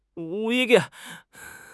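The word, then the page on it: duan4: tone 4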